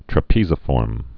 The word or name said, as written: trapeziform